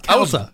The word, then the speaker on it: Calta: I think it's Calta. – Was it Calta? – Yeah, Calta. No, it's Calta. Mike Calta, yeah.